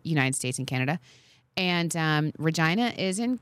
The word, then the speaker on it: Regina: It's pronounced Regina.